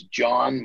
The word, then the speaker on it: Jon: Well, the John Anderson Hub would be my website, which is John. My name is spelled a little goofy. It's J-O-N, no H. So J-O-N hyphen, not to be confused with the underscore, Anderson, A-N-D-E-R-S-E-N.